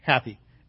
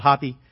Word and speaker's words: Hapi: What a wonderful name, Happy. H-A-P-I. Okay, some people pronounce it Hoppy. They don't know that A is an A sound or an A sound. It's not a A sound.